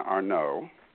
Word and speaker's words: Arnaud: Uh, spring and uh, I, I, wasn't quite sure how to pronounce it, but I believe it's Spring and Arnaud. Arnaud.